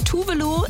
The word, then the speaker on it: Tove Lo: Und bei Tove Lo ist es Voulez-vous.